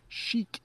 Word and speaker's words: chic: Chic. That's how we pronounce that.